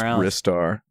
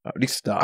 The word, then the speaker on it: Ristar: I just want to say it's definitively pronounced Ristar, by the way. I only know that because that's what it says on the title screen of the Japanese version. Ristar.